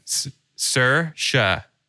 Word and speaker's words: Saoirse: Shursa for Jean Grey. Storm. – I don't think you've ever said her name right. Yeah. Ever one time. – Saoirse.